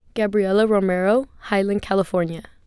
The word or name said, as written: Gabriella Romero